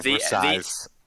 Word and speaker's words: Versailles: Versailles. – The S is silent, at the end there.